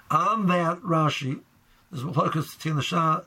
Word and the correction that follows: Shach: the Tach, and the Shaz. I said that wrong. The Shach and the Taz, is Rashi lav dafka, and Rashi just meant any Isser, and who does any Isser?